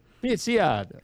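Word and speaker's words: Picciotto: Picciato? P-I-C-C-I-O. How would you? Picciato? – Pizziada. – Pizziada.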